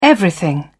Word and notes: everything: British English

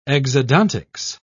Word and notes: exodontics: British English

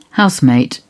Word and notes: housemate: British English

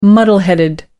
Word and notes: muddle headed: American English

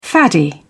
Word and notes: faddy: British English

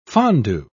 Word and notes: fondu: British English

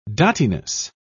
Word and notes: dottiness: British English

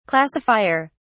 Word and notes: classifier: British English

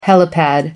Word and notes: helipad: British English

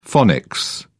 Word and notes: phonics: British English

